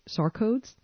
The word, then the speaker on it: sarcodes: But there's also a category of remedies where you use healthy organ material or tissues or metabolic factors, and these are called sarcodes. – Yes. – Is that pronounced correctly? – That's correct.